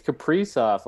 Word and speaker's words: Kaprizov: I think it's Kaprizov, like Capri Sun.